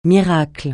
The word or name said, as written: miracle